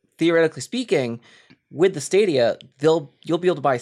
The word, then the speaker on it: Stadia: Fun fact: the reason it's pronounced Stadia is because Stadia is the plural form of stadium.